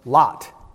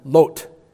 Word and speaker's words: Lot: How has it come about that we say his name Lot in English rather than Lot, as it appears not only in the Hebrew but even in the Greek translations? They all call him Lot.